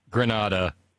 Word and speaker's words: Granada: And then finally, because I got it right, na-na-na-na, hey-hey, Granada is okay. So I didn't mispronounce Granada in Humboldt County.